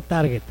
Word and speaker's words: target: Se dice target. Sí, a ver, aquí está el target. No, es target. En inglés se pronuncia target.